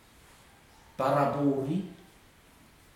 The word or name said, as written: parapu uri